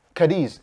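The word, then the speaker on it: Cádiz: Those players are now coming on to score two goals against Cadiz. Mm-hmm. Have I pronounced that right? – Correct.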